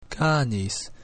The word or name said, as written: canis